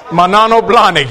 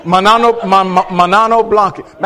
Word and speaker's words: Manolo Blahnik: How you pronounce that name? Mono Blanc. Manola Blahnik. I see. Y'all know those. You know them. You've been looking at them. Okay, Manolo Blahnik. Yeah. Manolo Blahnik.